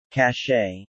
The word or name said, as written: Cachet